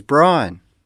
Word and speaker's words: Brian: Yes, my name is Brian. – Can you spell it for me? – B-R-I-A-N.